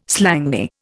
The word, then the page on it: slangy: Indian